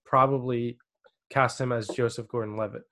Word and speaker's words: Gordon-Levitt: For you, I picked Joseph Gordon-Levitt.